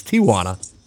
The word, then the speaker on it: Tijuana: Which is not Tijuana like most Americans pronounce it. It is Tijuana.